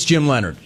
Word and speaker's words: Leonhard: Leonhard. – It's way more difficult than it needs to be. You know it's Jim Leonard.